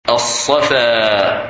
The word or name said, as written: Safa